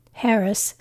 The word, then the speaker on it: Charis: Harris, spelled C-H-A-R-I-S with a silent C, is the Greek word for grace.